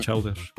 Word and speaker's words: Chowder: Entonces se llama Chowder. Así, C-H-O-W D-E-R. Se pronuncia Chowder.